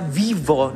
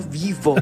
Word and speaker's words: vivo: Antonio Gala decía, estás viva, con, además que Antonio Gala era de las pocas personas que pronunciaba la V en este país, sí. – ¿No? Decía vivo, no vivo.